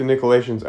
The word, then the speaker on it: Nicolaitans: You hate the Nicolaitans. I pronounce it Nicolaitan, which is, I think, how most people pronounce it. It's not exactly how it's spelled. Nicolaitans.